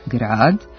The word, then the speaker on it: grad: Ukoliko je akcenat dugosilazni, grad, onda je reč o imenici koja označava urbano, naseljeno mesto, a ukoliko se izgovori sa kratkosilaznim akcentom grad, onda se misli na vrstu atmosferskih padavina.